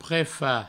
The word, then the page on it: prefach: prefa; provençal